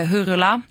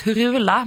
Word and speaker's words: Hurula: Hurula. Jag har alltid sagt Hurula, men jag har idag lärt mig att det uttalas Hurula. Hurula.